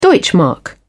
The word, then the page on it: deutschmark: British English